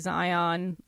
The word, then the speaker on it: Xion: But it's funny, too, because there's a character in the game called Xion, but it's spelled X-I-O-N. So, like, I would never pick up how to Zion. – Pronounce that.